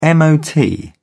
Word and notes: mot: British English

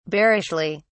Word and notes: bearishly: British English

